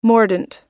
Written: mordent

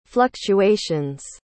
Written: fluctuations

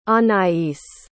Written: Anaïs

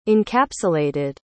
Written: encapsulated

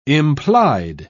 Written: implied

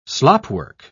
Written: slopwork

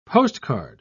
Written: postcard

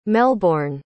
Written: melborn

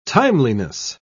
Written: timeliness

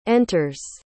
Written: enters

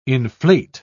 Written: inflate

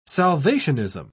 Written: salvationism

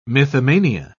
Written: mythomania

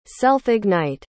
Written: self-ignite

